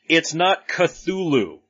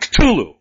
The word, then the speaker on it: Cthulhu: Yes, it's supposed to be pronounced with the tongue at the top of your mouth and like you're spitting the name out. Cthulhu.